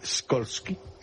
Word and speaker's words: Skolsky: ¿Cómo se pronunciaría en polaco Skolsky? – Skolsky. Como tú lo has dicho. – Bueno, Skolsky, fondo a la derecha.